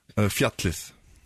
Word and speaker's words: Fjallið: Fjallið.